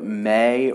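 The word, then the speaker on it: Mei: And I don't know if I'm saying her name right, but May, or it's M-E-I. – So I would agree with May. – I would say May, but she is my absolute favorite.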